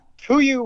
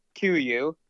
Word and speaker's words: KUIU: and he called it KUIU, and it's a direct to consumer product line, so I don't think you can find KUIU in any stores. It, it's spelled uh, K U I U, if you guys have never heard of this.